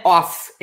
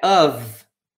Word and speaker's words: of: Lembra que essa palavra aqui não é of, é of. Of. Of. É com V que se pronuncia isso aqui, of, tá?